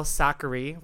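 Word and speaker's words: Sakkari: Our girl Sakari, rhymes with daiquiri, defeated the Aussie Tomljanovic pretty convincingly.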